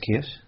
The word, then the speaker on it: kirsch: A little bit of kirsch in there.